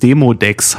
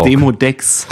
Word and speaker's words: Demodex: Ich habe es falsch ausgesprochen. Demodex heißt es. – Oh. – Na, dann kenne ich's. – Ja, dann kenne ich's. Also Demodox. Nicht zu verwechseln mit Desodox. – Demodex.